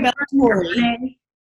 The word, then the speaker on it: Ballatore: Is it my name's Chloe Ballatore.